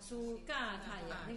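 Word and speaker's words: 苏伽多耶: 嘎塔呀，苏嘎塔呀，苏茄多耶，苏那个我又说那个茄不能念茄，都要念嘎，苏嘎塔呀，那个茄念成嘎的话不就对了吗？苏茄苏茄的茄不念茄，苏嘎塔牙不就有了吗？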